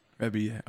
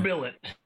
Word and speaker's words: Rebillet: I just learned how to pronounce his last name. – Yeah, I thought it was something else entirely. – Rebillet. – Okay. Rebillet.